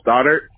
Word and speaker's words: Stoddart: Stoddard. – Okay, can you spell that for me? – S-T-O-D-D-A-R-T.